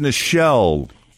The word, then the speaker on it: Nichelle: And Star Trek star Nichole Nichols. Which one was she? Do you know who she played? – Isn't it pronounced Nichelle? – Is it? Nichol- yeah, I think so.